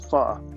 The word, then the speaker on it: pho: The problem with the pho was I guess they really pronounce it pho, but in my world, I call it pho.